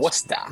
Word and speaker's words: Worcester: It's pronounced Worcester. – It's pronounced Worcester. Worcester. In Massachusetts.